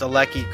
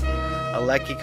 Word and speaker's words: Aleki: Notice I pronounced Aleki correctly this time. Aleki correctly.